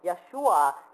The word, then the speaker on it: Yeshua: And that is why now they have proponents to go back to the original pronunciation of the Hebrew that is the closest, and that is to pronounce it as Yeshua, and that's closer to Joshua and the name of Jesus himself.